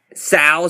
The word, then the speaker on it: Sal: Sal is my first name. S-A-L. – Sal. – Yeah, and my last name's Vickersbig. V-I-C-K-E-R-S, Big.